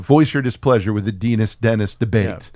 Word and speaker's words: Denis: His name is spelled D-E-N-I-S. – Okay. – I believe that should be pronounced Denis. – Denis. – Because you don't say penis.